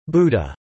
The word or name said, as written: Buddha